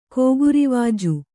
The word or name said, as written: ಕೋಗುರಿವಾಜು